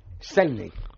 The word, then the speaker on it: Selznick: Y que tiene una anécdota que me ha hecho mucha gracia y que creo que es interesante, y es que Oselznik... – ¿Qué bien lo pronuncias? Sí, señor. No, se... favor, vete a tomar viento. – ¿De quién hablas? ¿Del productor? – Sí. – Selznick.